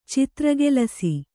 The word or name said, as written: ಚಿತ್ರಗೆಲಸ